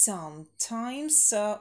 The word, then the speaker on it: sometimes: Just sometimes. Ударение на первый слог. Ну, вот здесь, что называется, лишь бы придраться. Но sometimes. Это такое популярное смещение ударения. И, признаться честно, мне тоже бывает. Хочется на второй слог.